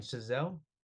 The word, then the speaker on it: Chazelle: Let me make sure I have his last name pronounced correctly, Damien Chazelle.